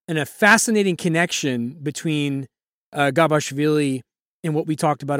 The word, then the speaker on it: Gabashvili: First round, he plays Temuraz Gashvili. Hope I pronounced that correctly. – Gabashvili, thank you.